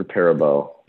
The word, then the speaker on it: Perabo: Piper Perabo.